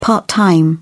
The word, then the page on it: part-time: British English